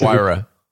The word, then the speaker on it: Huayra: It would not be a Huayra. I don't know how to pronounce the name. – It's Huayra. – Huayra, yeah.